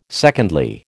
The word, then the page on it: secondly: American English